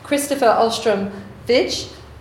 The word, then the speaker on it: Kristoffer Ahlstrom-Vij: I'm going to ask our three eminent speakers, Eric Kaufmann, Kristoffer Ahlstrom-Vij, did I say that right? – Yes, you did.